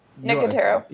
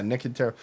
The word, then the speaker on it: Nicotero: In fact, I think it was Nicotero, or how do you say his name? – Nicotero. – Yeah, Nicotero.